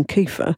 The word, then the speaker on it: kefir: I'm going to stick to the Welsh pronunciation, kefir.